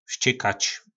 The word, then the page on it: vzcykac: po našimu